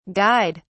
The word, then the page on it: guyed: British English